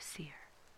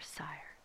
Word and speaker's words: Seir: These then are the records of the generations of Esau, the father of the Edomites, in the hill country of Seir, or Sire, depending on where you're from, how it's pronounced.